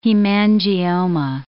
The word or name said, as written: hemangioma